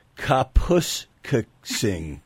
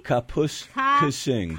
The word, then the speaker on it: Kapuskasing: K-A-P-U. – K-A-P-U. – Oh, wait a minute. She's schooling me now. It's Kapuskasing. Be careful with that. – Can you just spell it? K-A-P-U. – S-K-A. – S-K-A. – Sing. – Sing? – Yeah. – S-I-N-G. – Kapuskasing. – Kapuskasing.